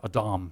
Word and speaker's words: Adam: Now we have to stop right there, because the word man is the Hebrew word Adam, or Adam, as it would be pronounced there.